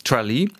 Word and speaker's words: Tralee: Tralee, wie spricht man es aus? – Tralee. – Ja.